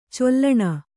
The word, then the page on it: ಚೀಲಣ: cīlaṇa